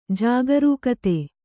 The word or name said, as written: ಜಾಗರೂಕತೆ